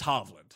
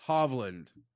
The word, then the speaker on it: Hovland: It's Hovland. – Hovland.